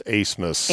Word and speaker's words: Abmas: A-B-M-A-S, pronounced Asemus.